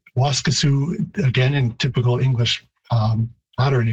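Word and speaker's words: Waskesiu: So Waskesu, it would be a longer vowel in Cree, even though the stress is on the first syllable. Waskesu.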